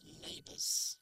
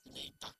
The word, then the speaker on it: neighbor: To live in peace with your neighbors. ¿Qué significa neighbors? – Vecinos. – Muy bien. Y se pronuncia neighbor. – Neighbor. – En este sonido de a, neighbor, y no neighbor.